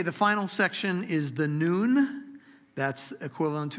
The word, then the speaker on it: Nun: It is pronounced noon as if it were noon on the clock, but it's N-U-N. And there's a character in our Bibles who was known as the son of Noon. There's a running joke that Joshua didn't have the father because he was the son of Nun, N-U-N. But Noon is how you pronounce it.